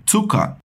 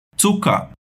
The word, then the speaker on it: Zucker: Zucker. Zucker. Percebeu bem como a gente faz esse som no começo? Quase como se você estivesse juntando um T e um S.